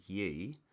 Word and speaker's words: Ye: Yeah, and no one's referring to him by his legally correct name of Yay. here's the quote from Kanye about his name change. He said, I believe Yay, although I think you'd pronounce it Ye in this context, is the most commonly used word in the Bible.